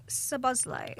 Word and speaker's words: Szoboszlai: Well, Dominic Szoboszlai.